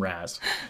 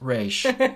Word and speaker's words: Ra's: I hate that those movies became so popular and they just call him Raz. And it's like, it's Ra's.